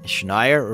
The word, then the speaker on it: Schneier: This is Bruce Schneier. – Schneier rhymes with frequent flyer.